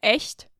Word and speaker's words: echt: And the thing here is that actually these two words that he gives, the two German words, have two different pronunciations. So it's echt, but acht. And the CHT after E is pronounced differently in German than after A.